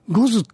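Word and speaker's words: 牛頭: で、そこで、招かれたのが、五頭天王と呼ばれる、あの、まあ、いわゆる、まあ、魔物の神さんみたいなやつですね、多分。その、五頭ってのはどんな字書くかというと、あの、五ってのは、牛です。牛の頭と書いて五頭と呼ぶんだけど、その五頭天王ってものを、まあ、招聘したわけですね。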